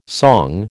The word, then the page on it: song: American English